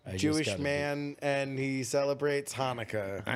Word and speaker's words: Hanukkah: Chinooka. – Don't say it. Say Hanukkah. That's not how you pronounce it. It's Hanukkah.